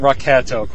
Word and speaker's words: Rocketo: Well, I pronounce it Rocketto, quite frankly. I, I, I, went to Frank Espinosa's website, and there was a big discussion on that because I was curious because I pronounced it that way on my podcast, and several people called me on it. And if, if I read, read his phonetic spelling, it is Rocketto.